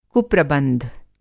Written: कुप्रबंध

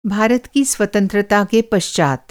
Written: पश्चात

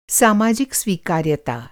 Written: स्वीकार्यता

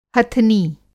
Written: हथनी

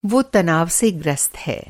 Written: ग्रस्त

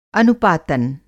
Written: अनुपातन

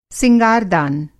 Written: सिंगारदान

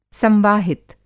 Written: संवाहित

